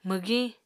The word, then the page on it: mwgiñ: Apatani